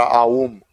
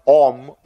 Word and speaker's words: Aum: Big slap over the head for the people who keep on pronouncing the mantra Aum, Om. Because it's not Om.